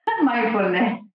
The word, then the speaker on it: mindfulness: Mindfulness.